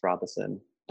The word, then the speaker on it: Robeson: But it's my last name, it's spelled like Robison, pronounced Robison. But also, last night I actually found out that the first academic article I submitted is going to be published.